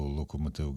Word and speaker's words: Локомотив: Це мені, до речі, нагадує, що музиканти знакомитого угорського рок-гурту Локомотив ГТ, вони з гордістю завжди вказували, що фендер. – А як же? – Фендер, так. – Як вони кажуть, Локомотив. Вони кажуть, що треба наголос має бути на перше.